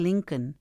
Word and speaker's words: Lincoln: Otra cosa es que se les olvida la L en el apellido de Lincoln. Como les dije antes, la L casi no se escucha, pero sí se escribe.